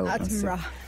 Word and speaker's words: Attenborough: O ti fa l'effetto shock, come per esempio su di me, quello di David Attenborough, lo pronuncio sempre così, poi magari un giorno ci chiamerà di là. – Attenborough. – Attenborough.